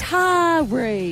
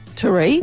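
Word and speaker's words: Taree: It's Tari. – Yeah. – Exactly. – Two equal syllables, not Tari.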